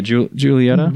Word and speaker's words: Giulietta: Yeah, Giulietta.